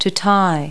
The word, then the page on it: tie: verb